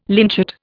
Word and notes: lynchet: Indian

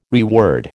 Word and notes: reword: American English

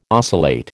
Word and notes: oscillate: American English

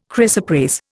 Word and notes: chrysoprase: Indian

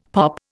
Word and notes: POP: Indian

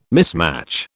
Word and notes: mismatch: British English

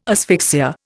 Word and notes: asphyxia: Indian

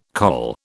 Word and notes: col: British English

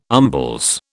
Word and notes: umbles: British English

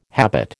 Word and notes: habit: American English

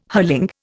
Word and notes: hurling: Indian

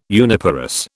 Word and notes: uniparous: British English

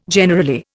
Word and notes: generally: Indian

